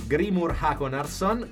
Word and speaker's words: Grímur Hákonarson: Poi abbiamo visto Rams di Grímur Hákonarson, che è islandese, non so se è nelle tue corde anche l'islandese. – Più o meno. L'hai detto bene.